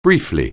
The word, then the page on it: briefly: anglais